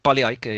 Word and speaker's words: Pali Aike: did, I, did I pronounce that correctly? Uh, pretty much, yeah. I think Pali Aike, yeah.